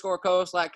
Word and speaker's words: Koselak: At Corbett, KORBETT _ Koselak, KOSELAK on Twitter.